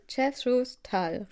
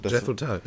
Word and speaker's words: Jethro Tull: Warte, Jethro Tull, richtig? – Genau. – Jethro Tull, ja.